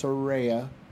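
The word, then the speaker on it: Saraya: Soraya. People say it right. It's Soraya.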